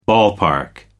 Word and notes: ballpark: American English

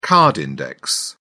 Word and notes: card index: British English

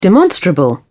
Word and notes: demonstrable: British English

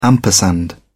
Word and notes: ampersand: British English

